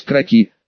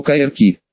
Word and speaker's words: кроки: Значит, примерный чертеж местности правильно называть кроки, с ударением на последний слог, а я всю жизнь считал, что кайрки.